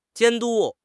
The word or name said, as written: 监督